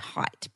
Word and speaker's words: Haidt: But he was – and it's spelt H-A-I-D-T, height, but it's pronounced height.